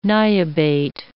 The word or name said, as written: niobate